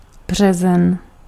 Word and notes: maaliskuu: /ˈmɑː.lisˌkuː/

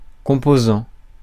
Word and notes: composant: /kɔ̃.po.zɑ̃/; France

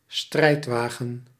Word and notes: charriot: France; /ʃa.ʁjo/

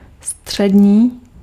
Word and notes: střední: /str̝̊ɛdɲiː/

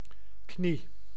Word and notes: knie: [kniː]; /kni/; /kni:/

